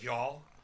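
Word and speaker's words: Mjoll: It's M-J-O-L-L, so it's like Y'all.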